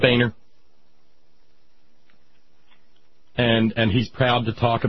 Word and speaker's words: Boehner: But I spelled out Boehner, Andy.